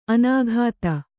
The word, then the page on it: ಅನುಗತಿ: anugati